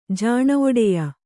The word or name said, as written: ಜಾಣೊಡೆಯ